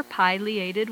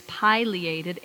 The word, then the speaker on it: pileated: I've always called it pileated, probably because I've internalized that old English class rule that when only one consonant separates vowels, the first vowel is supposed to be long.